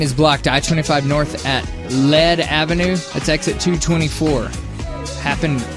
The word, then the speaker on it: Lead: It's actually pronounced lead. – Is it lead or lead? – Lead. – It's lead.